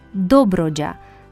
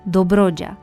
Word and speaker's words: Dobrogea: Și dacă am vorbit de nume, voi vorbi și de numele regiunii românești, care se pronunță conform dicționarului ortografic, ortoepic și morfologic, cu accent pe primul O, Dobrogea, nu pe al doilea, Dobrogea.